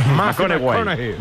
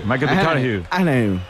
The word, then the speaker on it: McConaughey: ¿Mateo McConaughey? – Sí, sí, sí, sí. – Hay que ir corrigiendo la pronunciación. – McConaughey. – McConaughey.